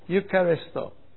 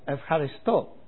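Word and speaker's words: Evharisto (ευχαριστώ): Eucharisto. Except they don't pronounce it like that. Evharisto. It's the same spelling.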